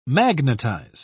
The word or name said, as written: magnetize